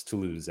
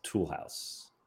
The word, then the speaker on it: Toulouse: Andy somehow f- decided to pronounce Toulouse as Toolhouse.